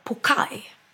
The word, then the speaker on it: poke: Men då uttalar jag den helt fel. Pokeball. För den uttalas pokai. – Va? – Ja. – Pokai bowl. – Hur konstigt. Stavas poke bowl med W. Och uttalas pokai.